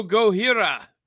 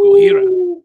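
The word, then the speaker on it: Gohira (ゴジラ): Go, go, go, Hira, says Zentron. Zentron. That's how you pronounce Godzilla in Japanese. Ooh, Gohira.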